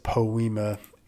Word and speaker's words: poema: So that is how you pronounce it. It is poema.